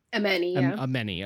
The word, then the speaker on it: Ameni: You know, there's this point where Armani, is that how you pronounce her name? – Ameni.